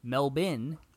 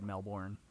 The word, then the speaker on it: Melbourne: One December morning in 1967, the Australian Prime Minister decided to go for a swim off the coast of Melbourne, I have pronunciation here for me, Melbourne. – Oh, Melbourne. – There we go. Yeah, Melbourne's in here, okay? – Okay, sorry. A tale of two cities, my bad. – And the writer actually put pronunciation for me. She knew I would have said Melbourne.